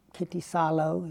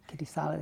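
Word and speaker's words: Kittisaro: And then he says, well, you know, Kittisalo, he pronounced it like that. Kittisalo, they don't pronounce R's. Or kind of L's.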